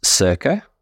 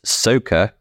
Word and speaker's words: Söka: So it's pronounced Söka, and it's a Swedish Söka. Word to mean to search for or to seek. And the reason why it's not pronounced Söka, as some people like to say, it's because there's an umlaut.